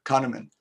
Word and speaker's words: Kahneman: And the Nobel Prize winning economist, I think it was Kahnweiler, I hope I got the name right, proved to the Nobel laureates. Say again? – Kahneman.